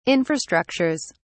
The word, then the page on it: infrastructures: British English